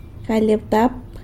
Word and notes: galy abtap: sanlucasquiavini

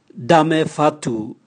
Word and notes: dame vatu: Raga

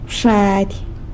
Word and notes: bxady: sanlucasquiavini

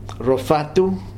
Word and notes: RoFatu: Futuna

